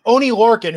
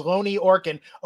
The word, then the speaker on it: Oney Lorcan: Oni, Lor- Oney Lorcan, who I thought was Loni Orkin. Oney Lorcan.